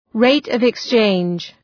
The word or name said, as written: rate-of-exchange